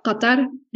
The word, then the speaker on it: Qatar: Somebody is going to like reach out and be like, that's not how you say it. So just in Arabic, it's Qatar. And so it's like very hard to transliterate.